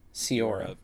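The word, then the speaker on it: Sciorra: Annabella Sciarro, is that how you say her name? – Sciarro, who worked with Ferrara on The Addiction, yeah.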